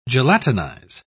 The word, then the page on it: gelatinize: British English